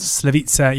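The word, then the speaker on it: Slaviša: Slavica Jokanovic's Blades, who... I mean, this will be fairly short because it's the first time I've ever heard his first name rhyme with pizza.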